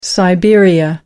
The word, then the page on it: siberia: American English